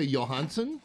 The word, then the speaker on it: Johansson: Do you say Johansson? – Johansson, yeah.